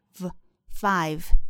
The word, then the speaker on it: five: 第一个呢，就是 five，大家在发的时候要注意，v 在这个地方是上齿碰下唇，v five。大家注意不要发成 w 的发音，发成 w，而是 v five。